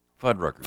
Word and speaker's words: Fuddruckers: Fuddruckers? – Fuddruckers.